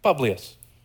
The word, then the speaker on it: Publius: New character now, Publius. Like this guy. He rhymes with Julius. Publius and Julius.